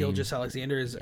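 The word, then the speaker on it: Gilgeous-Alexander: Gilgeous Alexander, he's a stud.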